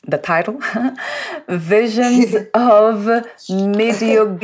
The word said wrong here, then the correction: Medjugorje: You help me, please. – Medjugorje. Visions of Medjugorje.